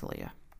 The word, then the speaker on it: Aaliyah: Okay, see, it pronounces some weird. That's Aaliyah.